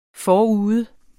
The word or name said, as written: forude